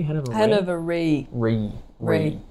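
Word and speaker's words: Hannover Re: Hanover Re? – Hanover Re.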